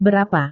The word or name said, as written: berapa